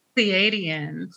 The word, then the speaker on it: Pleiadian: How do we pronounce it? – Pleiadian. – Pleiadian, because star seeds are a pretty common phrase right now.